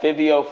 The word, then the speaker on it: Fivio: I think it's, yeah, Fivio Foreign.